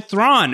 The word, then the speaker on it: Thrawn: It's busting out, Grand Admiral Thrawn, who, Latanya said to me, rhymes with prawn, but he's no shrimp in the Star Wars universe, was the text that I received from Latanya.